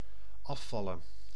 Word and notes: afvallen: /ˈɑˌfɑlə(n)/

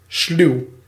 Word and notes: cunning: [ˈkʌ.nɪŋ]; American English; English

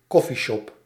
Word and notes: coffeeshop: /kɔ.fi.ʃɔp/

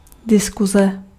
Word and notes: diskuze: IPA: /dɪskʊzɛ/

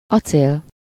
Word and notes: staal: [staːl]; /sta:l/

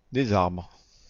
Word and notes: arbres: /aʁbʁ/

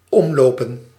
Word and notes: omlopen: [ˈɔm.loː.pə(n)]

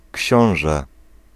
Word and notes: książę: [ˈkʲɕɔ̃w̃ʐɛ]; /ˈcɕɔ̃.w̃ʒɛ/

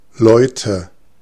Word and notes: men: /mɛn/

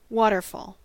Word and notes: waterfall: [ˈwɑtɚˌfɔl]; /ˈwɔːtəfɔːl/; /ˈwɑtɚfɔl/

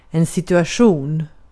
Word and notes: asema: [ˈɑsemɑ]

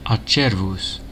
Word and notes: mass: /mæs/; American English

